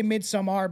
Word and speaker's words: Midsommar: Do you pronounce it Midsummer or Midsommar? – I say Midsommar because of you.